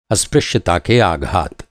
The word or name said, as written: आघात